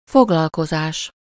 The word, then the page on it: foglalkozás: /ˈfoɡlɒlkozaːʃ/; /fo.ɡlɒl.ko.zaːs/